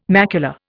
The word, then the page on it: macula: Indian